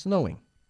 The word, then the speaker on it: snowing: Me ha encantado cómo has pronunciado el verbo snow. – Uh-huh. – Empezando con este sonido de la S. Snowing. Y no, reitero, repito, por enésima vez, es snowing.